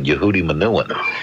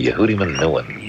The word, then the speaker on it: Yehudi Menuhin: I always thought of that it sounded like it had more of a sing to it if you pronounce it Yehudi Menuhin. And that's, you know, it sort of sings that way. Yehudi Menuhin.